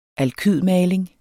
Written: alkydmaling

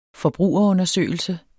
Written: forbrugerundersøgelse

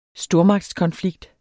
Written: stormagtskonflikt

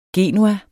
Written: genua

